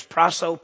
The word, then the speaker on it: prasso: The next word, if you go to the next slide, is prasso, P-R-A-S-S-O.